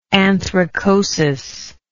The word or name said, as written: anthracosis